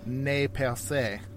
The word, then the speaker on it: Nez Perce: Have you ever heard people who aren't from the area, a lot of them will use the French pronunciation? – I hear Nez Perce a lot. I don't think that's the French pronunciation. – Yeah, no. In the French, a lot of people would say "Ne Perce."